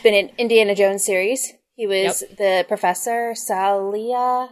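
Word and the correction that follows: Sallah: I can't pronounce names. – Sala. – So bad. Sala. Yeah.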